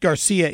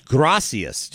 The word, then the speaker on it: Gracias: But it's not Garcia, it's Gracias, just like thank you Gracias. – In Spanish.